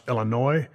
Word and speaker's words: Illinois: And yes, to everybody that lives in the great state of Illinois, I actually know that it's pronounced Illinois.